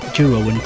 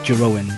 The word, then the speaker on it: Jeroen: Anyway, I think the correct Dutch pronunciation is Jeroen, I think it's Jeroen, but probably in English it's Jeroen.